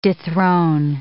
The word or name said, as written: dethrone